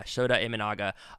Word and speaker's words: Shota Imanaga: Is it Shota Imanaga? Is that how you say his name? Yeah, Shota Imanaga.